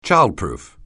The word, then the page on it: childproof: anglais